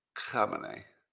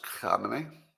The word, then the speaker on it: Khamenei: Because they have moral and complete, it's pronounced Khamenei. Pronounced Khamenei? Okay. I'll go with that.